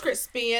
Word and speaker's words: Chrispy: My name is Cupcake, and my name is Crispy, and that's spelled C-H-R-I-S-P-Y.